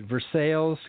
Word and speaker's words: Versailles: That's how they pronounce it. Versailles, Kentucky. – But it's spelled like Versailles in France. – Well, it's spelled like Versailles, but they can say it the way they want.